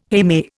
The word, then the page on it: heme: Indian